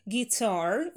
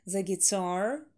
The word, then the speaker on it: guitar: Guitar. Ударение на второй слог. The guitar, the guitar, the guitar.